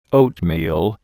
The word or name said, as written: oatmeal